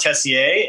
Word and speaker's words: Tessier: Well, if you were French, you would definitely call it Tessier. And because William Gibson is Canadian, I assume that he's doing the French pronunciation.